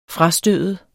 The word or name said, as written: frastøde